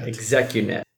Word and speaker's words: Xecunet: So today's guest, we have Xecunet. – Xecunet. – Yeah, spelled a bit weird, but that's how it's pronounced.